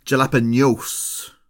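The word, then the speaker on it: jalapeños: Jalapenos. There's a little tilde over the end.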